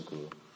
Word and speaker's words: Koo: But it spells like K-O-O, but it's like, sounds like hard G sound, Mr. Gu.